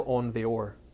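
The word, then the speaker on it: Aun Weor: Oh, Samael Onvior, A-U-N... And then W-E-O-R, which is Hebrew. It's a Hebrew name. We pronounce it Samael Onvior.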